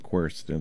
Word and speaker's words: quercetin: It's spelled Q-U-E-R-C-E-T-I-N, quercetin.